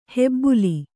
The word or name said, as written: ಹೆಬ್ಬುಲಿ